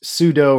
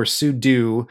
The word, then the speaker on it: sudo: I've heard it pronounced sudo or sudo. – I've always said sudo, yeah.